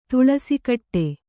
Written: ತುಳಸಿಕಟ್ಟೆ

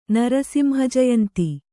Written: ನರಸಿಂಹಜಯಂತಿ

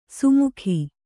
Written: ಸುಮುಖಿ

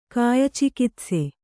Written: ಕಾಯಚಿಕಿತ್ಸೆ

